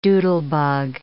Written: doodlebug

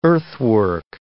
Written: earthwork

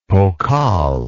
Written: pokal

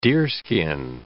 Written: deerskin

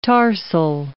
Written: tarsal

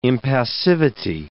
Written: impassivity

